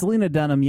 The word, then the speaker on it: Lena Dunham: Lena Dunham? Is that how you pronounce it? – It's Lena Dunham, yeah.